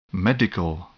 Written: medical

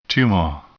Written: tumor

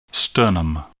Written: sternum

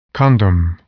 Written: condom

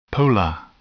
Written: polar

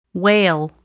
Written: whale

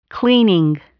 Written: cleaning

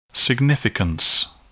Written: significance

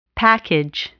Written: package

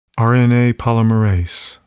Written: RNA polymerase

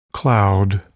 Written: cloud

